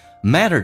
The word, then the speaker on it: matter: matter 这个单词 T 发成 D 这个发音，在美式发音当中，当两个元音中间所加的辅音要被浊化，叫 matter，同时后面属于轻度卷舌 matter。